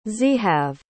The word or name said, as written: zehave